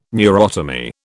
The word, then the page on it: neurotomy: British English